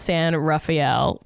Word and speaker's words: San Rafael: My family used to live in the city of San Rafael, pronounced San Rafael. San Rafael. Okay, I got it.